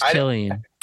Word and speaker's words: Cillian: Is it Cillian or Cillian? – It's pronounced Cillian.